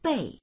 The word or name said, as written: bei4